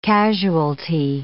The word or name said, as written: casualty